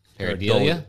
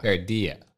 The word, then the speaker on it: pareidolia: Pareidolia? – Pareidolia. – Pareidia. Is it Spanish? – It's spelled very weird. – I've always heard it pronounced pareidia.